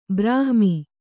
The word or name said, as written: ಬ್ರಾಹ್ಮಿ